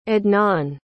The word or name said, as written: adnan